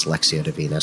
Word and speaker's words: Lectio Divina: First, the difference between Lectio Divina, and it's pronounced different ways. The way I pronounce it is Lectio Divina.